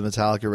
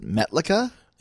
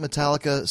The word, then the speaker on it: Metallica: I remember the first time a guy showed me the Metallica record, my friend was with me, pronounced it Metlica.